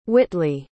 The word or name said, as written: whitley